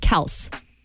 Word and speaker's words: Kelce: Jason Kelce, actually, I just found out that you pronounce it Kelce. On their podcast, their father said that it's actually pronounced Kelce, but he was sick of people saying it wrong, so he just stopped correcting them.